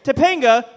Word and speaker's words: Topanga: First argument, Topanga rhymes with Jenga.